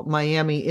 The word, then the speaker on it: Miami: So that's the Pitcher Mining District in, it's spelled Miami, M-I-A-M-I, but it was named for a man's wife, so it's pronounced Miami.